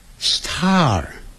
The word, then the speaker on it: star: 不要管它，这些字都不要管，我们只要学会 star star，因为 S-T-A-R 有一个 R 的音，结尾的时候然后要卷舌的，而且 S 后头有那个 T 的时候可不要念成 star，那个 T 就变音了，变成 D 的音， star， star。